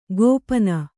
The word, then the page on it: ಗೋಪನ: kannada